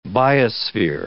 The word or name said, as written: biosphere